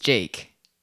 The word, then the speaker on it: JVKE: His name, when you look at it, might look like Jivika or JVKE or whatever. But it's actually just Jake.